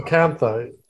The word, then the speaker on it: chalcanthite: Cal- um, chalcanthite.